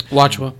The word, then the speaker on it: Nwachukwu: His name's Noble Nwachukwu. No, I can't say his name. – Nwachukwu.